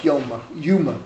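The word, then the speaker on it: Yoma: And the Mishnah here, the Elu Lignai, I'm quoting Lamed Chet Amud Aleph, Yuma it's pronounced.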